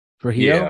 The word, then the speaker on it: Trujillo: I applaud the band's, I mean, apparently Rob Trollo, actually his last name Trollo, the bassist, forget how to say his name, Robert, I think it's Trujillo, isn't it? – Trujillo. – Yeah, Trujillo, yeah.